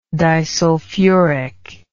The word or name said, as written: disulfuric